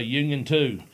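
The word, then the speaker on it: Unioto: And he said, just say Union 2. That's what it is. Basically, you know, so it's at the high school, the Union 2 high school there. Unitu, that's it. Say it like told you.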